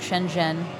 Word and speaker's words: Shenzhen: I think Shanghai and Shenzhen is on the schedule.